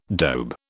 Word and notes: dobe: British English

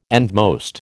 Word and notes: endmost: American English

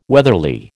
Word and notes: weatherly: American English

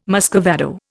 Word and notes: muscovado: Indian

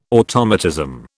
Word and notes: automatism: British English

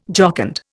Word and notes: jocund: Indian

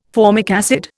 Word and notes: formic acid: Indian

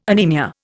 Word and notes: anaemia: Indian

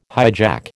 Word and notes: hijack: American English

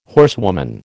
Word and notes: horsewoman: American English

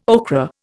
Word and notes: okra: Indian

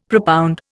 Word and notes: propound: Indian

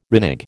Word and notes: renege: American English

